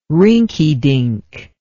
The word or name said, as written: rinkydink